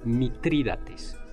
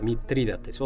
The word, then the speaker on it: Mitrídates: Es Mitrídates. – Así es. Ese, ahí está, Mitrídates, otro nombre.